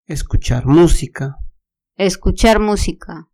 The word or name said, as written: escuchar musica